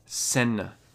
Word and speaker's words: Seine: I may have butchered the Seine River. It's the Seine River. The River Seine. That's how they pronounce it in French, I think. I'm pretty sure.